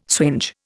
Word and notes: swinge: Indian

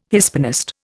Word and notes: Hispanist: Indian

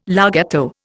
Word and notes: larghetto: Indian

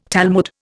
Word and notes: Talmud: Indian

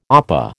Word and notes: opah: American English

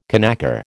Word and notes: conacre: American English

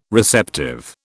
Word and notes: receptive: British English

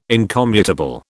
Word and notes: incommutable: British English

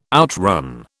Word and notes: outrun: British English

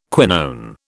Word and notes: quinone: British English

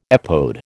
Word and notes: epode: American English

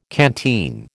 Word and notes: canteen: American English

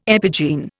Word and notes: epigene: Indian

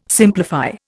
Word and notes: simplify: Indian

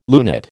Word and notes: lunate: American English

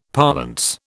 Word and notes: parlance: British English

